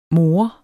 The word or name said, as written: mor